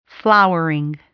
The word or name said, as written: flowering